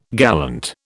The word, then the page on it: gallant: British English